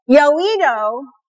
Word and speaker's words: Yoido: Yoido, did I say it right? Okay, Yoido Church in Seoul is the largest congregation.